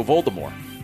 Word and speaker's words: Voldemort: So Voldemort.